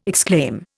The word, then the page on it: exclaim: Indian